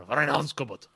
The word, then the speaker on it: Reinheitsgebot: And you got to have the little emphasis on there, Reinheitsgebot.